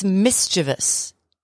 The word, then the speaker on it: mischievous: I've always said mischievous. – Yeah. – It's only three syllables. It's mischievous.